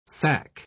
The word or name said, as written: sack